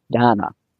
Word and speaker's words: Dana: Her name, and it's so hard to pronounce because it's spelled D-A-N-A, and it's not Dana, but it's not Donna. It's Donna.